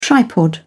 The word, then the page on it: tripod: British English